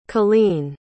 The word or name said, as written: cailene